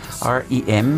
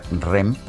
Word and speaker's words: REM: REM, me preguntan por qué lo pronuncio así. Bueno, así se pronuncia en inglés. Eh, hay mucha gente en México que lo pronuncia REM porque son pues así son las ah, iniciales de la... Ah, eh, así se pronunciaría en español, españolizadas estas iniciales, REM.